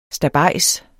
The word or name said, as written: stabejs